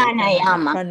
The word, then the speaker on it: Pranayama: Pranayama. – Pranayama.